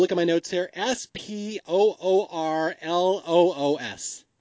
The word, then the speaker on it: Spoorloos: Yeah, I looked it up on Google one day. I'm like, I have to know how to pronounce this damn movie. And so it's Spoorloos.